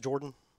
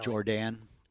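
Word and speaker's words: Jourdan: Is it pronounced Jordan? – Yes. – It's a little strange. – Not spelling. – Jordan. – Well, there's a U in there.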